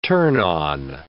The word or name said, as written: turn on